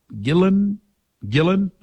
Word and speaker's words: Gillan: I think it's Gillan or Gila. And it, no, not Robert Plant. Is it Ian Gillan? Gillan? Okay.